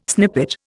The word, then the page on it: snippet: Indian